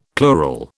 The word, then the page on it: plural: British English